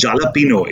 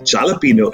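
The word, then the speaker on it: jalapeño: For example, people ordering pizzas or sandwiches say, I want jalapeno. It's not jalapeno, it's jalapeno.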